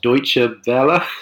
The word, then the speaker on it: Deutsche Welle: Our special guest this week is the London political correspondent for the doubtless mispronounced Deutsche Welle. – You did a good job there.